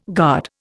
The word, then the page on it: guard: Indian